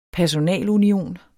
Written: personalunion

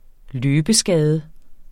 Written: løbeskade

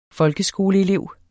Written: folkeskoleelev

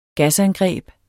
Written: gasangreb

